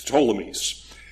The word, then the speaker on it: Ptolemies: There's a silent P and then T-O-L-E-M-I-E-S, the Ptolemies.